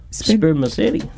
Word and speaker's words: spermaceti: Spermaceti. – It sounds like... – Henu. – Spermaceti, yeah.